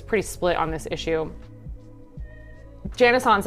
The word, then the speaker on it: Janice Hahn: Janice Hahn, supervisor.